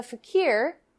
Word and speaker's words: fakir: Well, that's how you pronounce that word. I thought it was faker. Yeah, well, fakir is a Middle Eastern term.